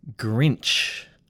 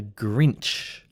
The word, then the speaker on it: Grinch: So if you know someone who gets grumpy or dislikes Christmas, just for fun, you can call him or her a Grinch. Let's go through the pronunciation because it's a bit tricky. So we have a consonant cluster, a GR cluster, grr, grr, grr. Then we have the sound inch.